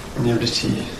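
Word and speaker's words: Nirṛti: That look with the dot is pronounced something like really Niriti.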